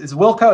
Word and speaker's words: WLCO: And that's where, you know, a lot of people in the Wilco, is Wilco, is that how you pronounce it? – Yeah. Yeah. Well done.